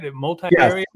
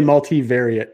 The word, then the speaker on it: multivariate: Multivariate. Is that right? Multivariate? Yes. – Yeah, usually multivariate testing.